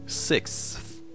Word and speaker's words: sixth: Sixth, que é o mais complicado de pronunciar, é sexto, fala-se six normalmente e depois o F ao é final. Sixth.